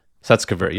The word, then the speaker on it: Sutskever: Sutskever, yes.